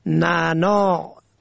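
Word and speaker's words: نعناع: در زبان فارسی از اثر کسرت کاربرد عین آن از تلفظ افتاده است، اما در فارسی شیوا باید آن را نعنا نوشت، یعنی نون عین الف عین، و تلفظ کرد.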